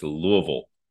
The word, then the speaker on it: Louisville: It's Louisville. It's one syllable. Louisville.